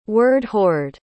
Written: word-hoard

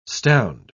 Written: stound

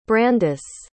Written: brandice

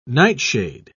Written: nightshade

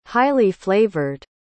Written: highly-flavored

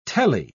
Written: tele

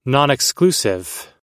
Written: nonexclusive

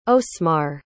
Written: osmarr

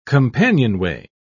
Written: companionway

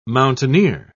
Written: mountaineer

